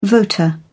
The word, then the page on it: voter: British English